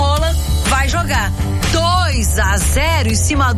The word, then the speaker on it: Haaland: Lembra que a gente falou sobre a pronúncia do nome do Haaland, que era Haaland? – Aham.